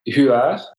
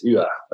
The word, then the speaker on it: Huwart: So my last name is, if you want to say it in the Belgian way, is Huwart. Mm-hmm. If you want more to put a little more French chic in it, you would say Huwart. Mm-hmm. But it's normally it's Huwart. It's how you would properly pronounce it.